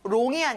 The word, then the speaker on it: long: 이것도 long이 아니라 어 발음을 정확하게 해보세요. long. Good job, everyone.